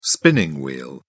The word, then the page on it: spinning wheel: British English